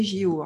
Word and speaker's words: JO: Et on dit les JO. Hein. On le dit comme ça. Je vous l'écris parce que c'est la façon dont on va le prononcer.